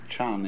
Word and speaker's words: Chan: And so this is being pronounced Chan in Chinese and Zen in Japanese.